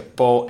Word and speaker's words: Pau: Lui no, deve insegnare a Francesco che si pronuncia, che si dice Po e non Pau, perché insomma.